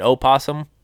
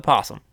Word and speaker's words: possum: It's just that somebody wants to say it opossum. The O is silent. They call it possum. – Yeah, that's how it started out for me. Was I was taught you say possum without the O.